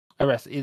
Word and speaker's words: Oresa: is a game called Beneath Aresia? Orsa? Or something? – How do you spell that? – No, O-R-E-S-A, sorry. – Oh, Beneath Aresia. – Aresia, that one, yeah.